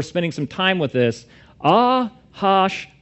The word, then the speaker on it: Ahasuerus: Because when you first look at that word, you're like, Ahasuerus. But that's not how you say it. This is a Hebrew word. And it's Ahashvarash.